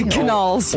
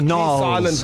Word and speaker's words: Gnarls: And then Gnarls Barkley. When did is the G silent? Is it Gnarls Barkley? – Gnarls. – Or Gnarls Barkley? – Gnarls. – It's not Gnarls.